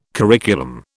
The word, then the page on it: curriculum: British English